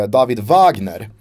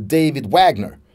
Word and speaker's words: Wagner: Det är tysk-amerikanen eh, David Wagner. Eh, jag väljer att säga det med tyskt uttal. Eh, och inte David Wagner.